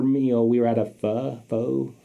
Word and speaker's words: pho: How do you say that shit? – Pho. – Pho. – Pho. Okay. My girl at the nail shop makes me pronounce it right. – Okay. – So, pho.